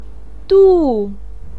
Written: du4

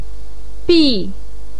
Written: bi4

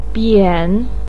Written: bian3

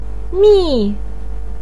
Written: mi4

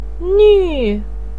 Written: nuu4

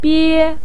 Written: bie1

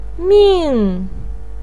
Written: ming4

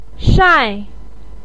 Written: shai4

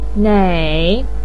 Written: nei3

